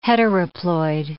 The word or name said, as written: heteroploid